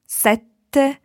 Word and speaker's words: sette: It's very similar, but number seven is double T, sete. And sete meaning thirst is single T. So when you say thirst, you can have a very long E, sete. While when you say number seven, sete, you need to be very short on your E to allow a very long T sound, sette.